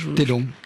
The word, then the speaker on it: Taedong: Comment on prononce d'ailleurs Taedong. – Taedong. – Le fleuve Taedong.